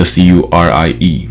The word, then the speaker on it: Curie: 我就擅自把这个 C U R I E 念成。 Curie 还是他的重音要放后面。好，然后他又同时强调哦，居里夫人是比较多的期刊会用的，就是 Madam Curie 是比较多人会用的。